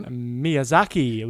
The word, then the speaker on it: Miyazaki: Miyazaki. – Miyazaki.